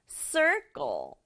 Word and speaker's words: circle: 첫 번째는 circle, circle. 앞에 있는 C는 S 소리 나고요. 뒤에 있는 C는 K 소리 나요.